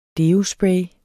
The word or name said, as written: deospray